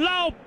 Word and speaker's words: Lowe: Sí, esa es una de las cosas más raras, porque tú dices Nathaniel Lowe, se escribe igual el apellido, L-O-W-E. Ahora, hay que preguntarle a ellos porque uno se pronuncia Lowe y el otro Lowe, pero es así exactamente.